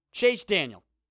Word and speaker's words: Daniel: I didn't even say his name right. It's Chase Daniel. There's not even an S at the end of his name, which is weird to me, right? Usually, every time you say his name, you think Chase Daniels. No, Chase Daniel.